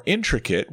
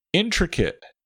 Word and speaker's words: intricate: Intricate. Intricate has a short I on all three syllables, even the one with the letter A.